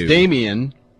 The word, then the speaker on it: Damion: Oh, and by the way, it's Damian, even though it's spelled Damion.